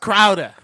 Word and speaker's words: Crowder: It's pronounced Crowder. – Crowder. – Crowder. – Crowder. Jay Crowder. Because he played in Boston. Remember? – Yeah. – In Boston. – Oh. – Crowder. Clam Crowder. Oh. Clam Crowder.